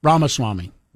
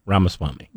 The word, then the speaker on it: Ramaswamy: Ramaswamy. – Ramaswamy.